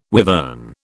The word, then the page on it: wivern: British English